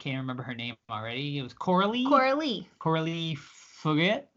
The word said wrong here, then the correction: Fargeat: it, it's Coralie Fargeat.